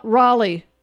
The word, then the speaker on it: Rollye: Raleigh. – Raleigh, or this W-O-L-L-Y? – R-O-L-L-Y-E.